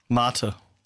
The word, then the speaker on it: Mate: And Club Mater, is that how you say it, or Mate? – Mater. – Mater.